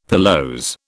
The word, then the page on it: pilose: British English